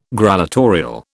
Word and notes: grallatorial: British English

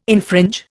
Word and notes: infringe: Indian

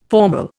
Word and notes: formal: Indian

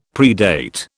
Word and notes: predate: British English

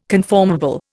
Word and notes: conformable: Indian

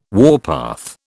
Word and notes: warpath: British English

